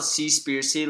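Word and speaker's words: Seaspiracy: The C-spiracy. – It is called C-spiracy, like conspiracy, but instead of starting with a C, it starts with C, C-spiracy. – S-E-A.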